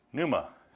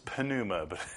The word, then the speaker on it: pneuma: Now, the word for spirit in Greek is, you might guess it, pneuma. Or if you really want to pronounce that P, it's pneuma. But they don't pronounce the P, do they?